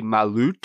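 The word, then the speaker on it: Malört: An umlaut over the O. So I don't even know if I'm pronouncing it correctly. – I believe it would be Malut.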